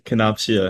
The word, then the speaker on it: Kenopsia: It's pronounced Canopsia. – Canopsia. Okay, I knew, I told myself I wasn't going to fuck that up, but I already did. Okay, Canopsia, my bad.